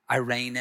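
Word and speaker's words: eirene: That peace, that word peace comes from a Greek word, Irene. Irene, if you want to pronounce it.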